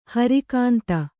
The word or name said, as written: ಹೊರೆಗುಂದು